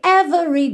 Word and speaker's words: every: 99,9% das vezes que a gente vê a palavra every, esse E do meio é mudo. Ou seja, do V a gente vai direto pro R. Every. Every, ok?